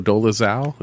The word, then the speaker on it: Dolezal: like Rachel Dolezal. Is that how you say her name? – Yeah, that's a, that, that, that's a little bit different.